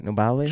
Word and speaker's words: Nobile: And then we moved upstairs to the Piano Nobile. Is that how you say it? Nobile? – Sure, yeah.